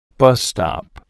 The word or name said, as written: bus stop